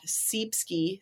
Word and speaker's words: Ceepski: So I am at Seepski, C-E-E-P-S-K-I. I'm going to spell it again because I think I got it wrong. C-E-E-P-S-K-I.